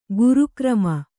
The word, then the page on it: ಗುರುಕ್ರಮ: guru krama